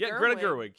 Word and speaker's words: Greta Gerwig: Great Greta Gerwig, right? Am I saying her name right? – I'm going to look it up. – Greta. – I think it's Greta Gerwig. Yep, Greta Gerwig.